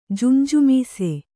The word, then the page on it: ಜುಂಜುಮೀಸೆ: Kannada